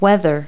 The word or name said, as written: weather